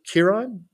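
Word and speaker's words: Kearon: And of course, our new rock star, Elder Curon, did I pronounce that right? Elder Curon? – That's how I believe it's pronounced. Yeah.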